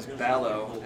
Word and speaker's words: Ballowe: If you want an explanation for that, is my last name is Ballowe, and everybody always slaughters it. So, website's Rhymes with Shallow.